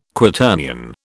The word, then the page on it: Quaternion: British English